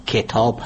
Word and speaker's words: کتاب: به طور مثال تلفظ درست واژه کتاب، کتاب هست، یعنی با کسره کاف تلفظ میشه نه با فتح.